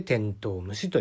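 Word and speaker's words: 天道虫: 漢字でですね、天の道の虫と書いて天頭虫と読むそうですね。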